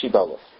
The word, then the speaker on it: Shibboleth: The password is Shibboleth. The Yomer Shibboleth. The people of Ephraim could not pronounce SH. They pronounced it as an S.